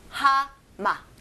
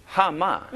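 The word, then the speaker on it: Ha-ma (하마): Ha-ma. – Ha-ma.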